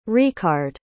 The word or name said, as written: recard